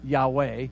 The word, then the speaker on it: Yahweh: And so when we talk about Jesus, it means YHWH, or in German we pronounce that Yahweh.